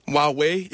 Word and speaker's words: Huawei: Huawei, ja.